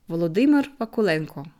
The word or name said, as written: Volodymyr Vakulenko